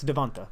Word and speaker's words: DeVonta: It's not Tay. It ends in an A. It's Devonta.